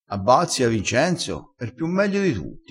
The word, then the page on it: piu meglio: dialettale